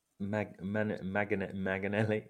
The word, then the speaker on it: Manganelli: You said Manelli. – Yeah. – No. Francesco. Okay. You're missing a syllable. – Manganelli.